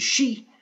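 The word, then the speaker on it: shi (四): This is because the Japanese pronunciation of 4, which is shi, sounds just like the word for death.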